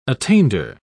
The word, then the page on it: attainder: British English